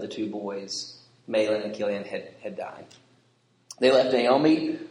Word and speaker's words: Chilion: Even though it looks like Chilion, it's pronounced with the K sound.